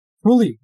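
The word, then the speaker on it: Proulx: I would have never thought that's how you pronounce it. Bro, those Frenches with the X's, man. I've got no clue. – It is baffling, but Prully.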